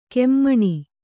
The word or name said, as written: ಖಮಣಿ